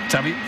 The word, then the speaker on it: Xavi: Xavi.